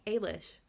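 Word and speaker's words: Eilis: They, when Ailish goes... Ailish goes, I know, to that meet... E throws you off. – It's because, yeah, it's spelled with an E, and so my brain, yeah.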